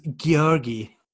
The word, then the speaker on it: Georg: Is Georgi. – Georgi. – Yeah, I mean, it looks like Georgie, but I think she called him Georg. Georgi. Yeah, the little boy was Georgi Jr., pretty much, right? – Yeah.